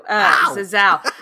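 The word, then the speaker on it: Zazzau: I did find someone on Twitter that told me, I think phonetically an American would say it like this. So that is what I'm going off. Zazao!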